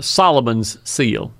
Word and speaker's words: Solomon's seal: You say ball of steel? – Solomon's seal. I didn't pronounce it very well, did I? – Solomon. – Solomon, like in the Bible.